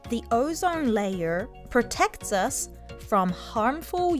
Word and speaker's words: ozone: Ozone layer。 – カタカナではオゾンですが、英語の発音はオゾーンといった感じです。